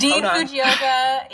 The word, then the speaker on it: Dean Fujioka: Dean Fujioka.